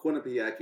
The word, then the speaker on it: Quinnipiac: It's called Quinnipiac University.